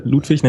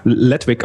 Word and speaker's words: Ludwig: Einer von denen heißt Ludwig, was ich immer sehr lustig finde, weil die ganzen Amerikaner hier Ludwig, nämlich Ledwig aussprechen.